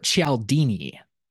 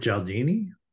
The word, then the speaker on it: Cialdini: Robert Cialdini. Is this one familiar to either of you? – Robert Cialdini. – Robert Cialdini. Cialdini.